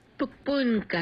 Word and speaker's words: 폭풍: 폭풍 같은 하루가 지나갔어. – The second syllable of storm, pung, pung, it's an eung. So make sure that eung sound, ung, is pronounced there.